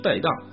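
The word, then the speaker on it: 骀荡: 春风带荡，带荡的带，一个马字旁，一个台啊，台湾的台，带荡啊，就是很舒畅的意思了啊。这个带应该是个多音字哈，它还有一个音是台，就是指的是列等马。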